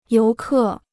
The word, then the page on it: 游客: yóu kè